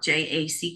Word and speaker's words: Jacki: Well, the main thing is to just Google my name, and it's spelled Jacki Gethner, which you will see on the screen when we're done.